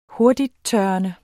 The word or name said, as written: hurtigttørrende